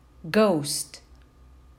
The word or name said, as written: ghost